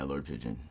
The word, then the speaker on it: Lord Pidjeon: Yeah, Lord Pidjeon. – Lord Pidjeon. Like Pidjeon, like the bird. Okay. I get confused because it's spelled like P-I-D-J-E-O-N.